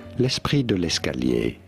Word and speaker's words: l'esprit de l'escalier: For reference, in France, in French, it is said as l'esprit. Yes, a silent T here, l'esprit meaning spirit, de l'escalier, de l'escalier, of the stairs. L'esprit de l'escalier is how you would say it in France.